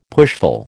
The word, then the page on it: pushful: American English